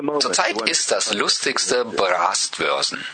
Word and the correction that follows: Brustwarzen: Brastbörsen. Was? Bra das ist nicht Brastbörsen? – Brustwarzen? Nippel? – Ah, Brustwarzen. Brustwarzen.